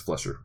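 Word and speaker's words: Fleischer: Am I saying that right, Flesher? – I think it's Flesher.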